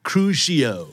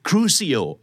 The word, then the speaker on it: crucio: crucio crucio crucio หรือ crucio